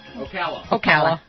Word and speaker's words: Ocala: Ocala? – How do you say that? Ocala, Florida? – Ocala.